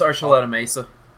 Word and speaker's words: Archuleta Mesa: claiming that a jointly operated human and alien underground facility exists under the, and how are we pronouncing this, guys? Archuleta Mesa? – Yeah, it's Archuleta Mesa. – Archuleta Mesa on the Colorado-New Mexico border,